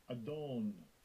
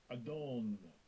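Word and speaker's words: Adon (אָדוֹן): Strong's H113, Adon, Adon.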